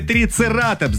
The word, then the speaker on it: трицератопс: Далее, вы сказали трицератопс, динозавр. Именно так его и надо произносить.